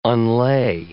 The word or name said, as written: unlay